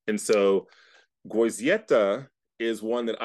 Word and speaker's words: Goizueta: You're close. It's Goizueta. – Goizueta. See, I knew it. – Yeah, it's hard. You got to make sure you pronounce every letter.